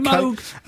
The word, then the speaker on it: Moog: That's right, it's pronounced Moog, incidentally. Okay. – Moog.